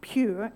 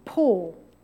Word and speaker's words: Pur: Now, the word to cast lots in Hebrew is the word P-U-R, from which we get, it's not pronounced pure, it's pronounced pour, like with a jug, something you pour, so pour in.